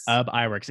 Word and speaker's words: Ub Iwerks: How do you pronounce it? – Ub Iwerks. – It's literally how it looks.